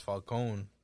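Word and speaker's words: Falcone: Like because of the fact that his name is pronounced Falcon. – Mm-hmm. Yeah, Falcon.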